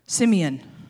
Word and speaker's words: Simeon: Son number two, Simeon.